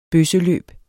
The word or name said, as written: bøsseløb